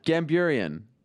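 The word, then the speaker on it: Gamburyan: You tried Gambinian. You tried to like sort of mumble it like I wouldn't notice. Gamburian. – Gamburian. Yes, yeah. That's it.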